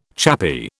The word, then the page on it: chappy: British English